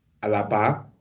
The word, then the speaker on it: Alaba: and I will say his name correctly because Alaba is the actually how you say his name.